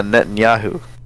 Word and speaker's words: Netanyahu: Shit, how much do you think Benjamin Netanyahu would make? – Benjamin what? – Let me just make sure I'm saying his name right. Benjamin... Yeah, Netanyahu.